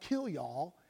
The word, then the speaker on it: kilyah: It's spelled K-I-L-Y-A. It's pronounced kilyah.